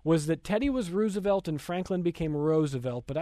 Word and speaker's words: Roosevelt: And that was the common pronunciation for a lot of people, but Teddy Roosevelt himself insisted, no, it is pronounced Roosevelt. So a little bit of a controversy, but that's how Teddy Roosevelt said his name. He said, like the flower, Roosevelt.